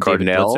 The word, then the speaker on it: Cardinale: Jerry Cardinale, am I saying that right? – And Cardinale.